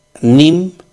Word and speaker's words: neem: Entonces, se pronuncia Nim y se escribe Neem con doble E.